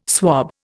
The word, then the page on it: swob: Indian